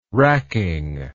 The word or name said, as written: racking